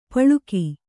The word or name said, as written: ಪಳಕ